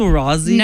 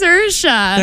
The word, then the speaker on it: Saoirse: Swarovski? – No, it's not Swarovski. – Swarovski Ronan. – It's Saoirse.